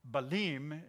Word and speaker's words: Balaam: You can say so many things about the portion of scripture that we're going to be studying about the prophet named Balaam, or Balim is the way you say it. I probably won't pronounce it like that anymore, but Balim is his name.